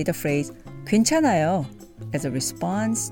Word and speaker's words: 괜찮아요: Which is pronounced 괜찮아요. It's okay. 괜찮아요.